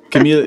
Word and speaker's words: Camille: Camille is originally from France, but is living in Portugal, Cascais.